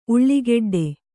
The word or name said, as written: ಉಳ್ಳಾಗೆಡ್ಡೆ